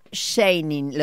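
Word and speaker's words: Scheinig: Jorge Eduardo Scheining, ¿lo dije bien? – Sí, muy bien.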